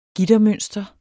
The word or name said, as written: gittermønster